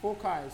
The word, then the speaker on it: foci: Okay, foci, sorry. – It's pronounced as key.